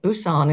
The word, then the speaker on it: Busan: And that's exactly how you say it Busan in South Korea.